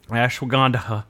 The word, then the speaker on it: Ashwagandha: Ashwagandha. Uh, by the way, the only reason I'm pronouncing "dha" is because the last three letters are DHA, and there's no way that you pronounce it.